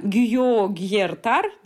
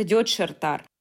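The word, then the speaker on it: gyógyszertár: Или аптека, написано Гюйо Гьертар, для нас это в венгерском это Дёдшертар а ударение в венгерских словах падает всегда на первый слог.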